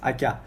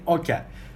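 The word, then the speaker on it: aka: In some cities of Uzbekistan, the pronunciation differs due to your dialect. For example, in western or eastern regions, we say Akya, but in Tashkent, in the capital of Uzbekistan, people usually say Okya.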